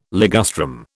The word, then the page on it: ligustrum: British English